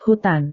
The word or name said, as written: hutan